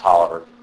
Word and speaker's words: Taliaferro: Contemporary philosopher Charles, it looks like Taliaferro, but I think it's pronounced Tolliver, he asked these questions.